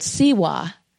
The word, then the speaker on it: tsewa: And there's a Tibetan word for this warmth, which is called siwa. It's spelled T-S-E-W-A. The T is silent, right?